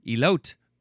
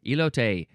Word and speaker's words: elote: Now, if I didn't actually know the pronunciation of this word, I would have said elote, because that's how it's spelled in English. That's how it would be pronounced in English. but this is a Spanish word, elote.